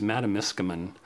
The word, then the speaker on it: Matamiskaman: So like many small Northern Ontario communities, Matamiskaman, the fictional town in the book. – That's how you pronounce it.